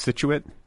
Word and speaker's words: Scituate: Is it pronounced Scituate? – Perfect. Yeah. Perfect.